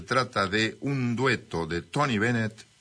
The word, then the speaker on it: Bennett: Observa mi pronunciación. – Sí. – Tony Bennett. – Bennett.